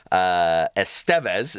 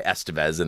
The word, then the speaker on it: Estevez: My parents are Edwin Alfredo Estevez is the Spanish pronunciation, but we go by Estevez in the United States.